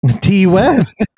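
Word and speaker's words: Ti West: Ty West. – It's pronounced made T. – T West.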